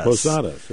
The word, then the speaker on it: posadas: Oh, yeah, posadas. – Posadas, yeah.